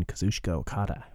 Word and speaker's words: Kazuchika Okada: It might still happen, but we have a new 5-time IWGP Heavyweight Champion in Kazuchika Okada, who beat Jay White.